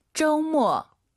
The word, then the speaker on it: 周末: 오유는 어우예요. 그리고 두 번째 글자는 모, 어, 어가 들려야 됩니다. 잘 들어보세요. – 周末. – 한 번 더. – 周末.